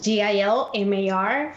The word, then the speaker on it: Gilmar: And that's Hilmar spelled with a G. Right. – With a G. – G-I-L-M-A-R.